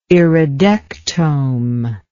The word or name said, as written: iridectome